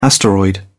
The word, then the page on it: asteroid: British English